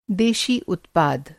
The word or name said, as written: देशी